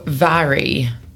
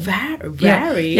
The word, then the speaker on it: Mhairi: Mhairi? – Yeah, because it's Scottish Gaelic, so... yeah, phonetically, I guess it would be like V-A-R-E, just for help, so Mhairi.